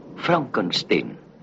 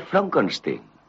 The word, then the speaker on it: Frankenstein: ¿Doctor Frankenstein? Frankenstein. ¿Me toma el pelo? No, se pronuncia Frankenstein.